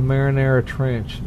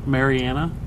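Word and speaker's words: Mariana Trench: The Marinara Trench makes an appearance in that movie. – Ooh. – They Marinara said it Trench. Wrong. They mispronounced it. – The Marinara? – The Marinara Trench. – Mariana? – No.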